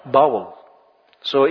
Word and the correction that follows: bowl: The, bowls. Bowels, yeah? So, is that correct pronunciation? Bowels? Bowl. Okay, bowl.